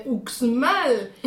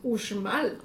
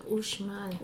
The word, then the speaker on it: Uxmal: Vas a Mérida, vas a Yucatán y la X se regresa otra vez a ser sh. Ah. Vas a las ruinas y tú, no Chichen Itza, pero vas a las de Uxmal. Y como buen pronunciano llegas y mira las pirámides de Uxmal. Y la gente se te acerca y educadamente te corrige y te dice, es Uxmal.